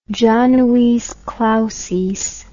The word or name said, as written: januis clausis